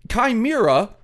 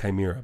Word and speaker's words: Chimera: It's Chimera, the language of cinema... Springsteen Ladies. – It's pronounced Chimera, but go on.